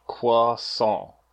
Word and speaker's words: croissant: Croissant. There's a T in there, but you don't say it.